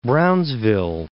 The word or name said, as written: Brownsville